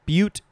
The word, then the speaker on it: butte: And then finally, we have the other word that is pronounced butte, but it is spelled B-U-T-T-E. Of course, all of us want to just say butt.